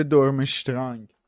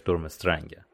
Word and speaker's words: دورمسترنگ: البته خب دورمسترنگ شده دورمشترنگ. – آره، خانم اسلامی دورمشترنگ نوشتن، ولی خب تلفظ صحیحش دورمسترنگه.